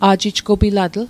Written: a'jijgopilatl

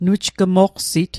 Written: nujgmoqsit

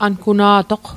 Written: anguna'toq/ankuna'toq

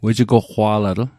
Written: wejigoqwa'latl

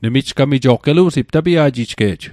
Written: tapia'ji'jge'j

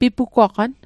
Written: pipugwaqan

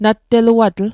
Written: natteluatl

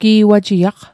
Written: gi'wajiaq

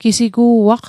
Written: gisigu'aq

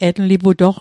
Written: etliputoq

